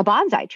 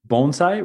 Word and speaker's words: bonsai: So it's actually bonsai rather than bonsai. I mean, that's a common misunderstanding in the West.